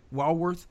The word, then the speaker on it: Walworth: Walworth? – Yes.